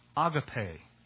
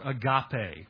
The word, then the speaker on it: agape: That's the verb form of the noun agape or agape, whichever way you want to pronounce it.